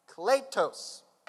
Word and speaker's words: kletos: The Greek word is, I'm going to do the best I can to pronounce it, kletos. K-L-E-T-O-S.